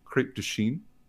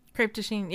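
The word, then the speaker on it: Crêpe de Chine: I pronounced this wrong like 3 weeks ago. Crepe de Chine. – Crepe de Chine, yeah.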